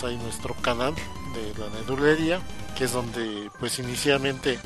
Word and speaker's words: Nerdulería: Nerdulería, Twitter, arroba Nerdulería, canal de YouTube, Nerdulería, con acento en la I, Ok.